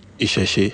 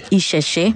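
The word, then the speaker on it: Iṣẹṣe: It's spelled I-S-E-S-E. How do you pronounce that? – Isheshe. – Isheshe.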